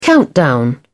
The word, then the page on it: countdown: British English